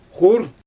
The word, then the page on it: xur: Xyzyl